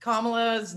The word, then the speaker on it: Kamala: And her, the first half of her name is like the word comma, right? So Kamala is her name, and Sotomayor called her Kamala.